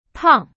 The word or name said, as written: 胖